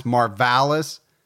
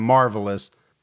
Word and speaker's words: Marvalis: Now, his name's actually pronounced Marvalis, but Gibby and I have decided that for the whole episode, we're going to call him Marvelous just because we like it better.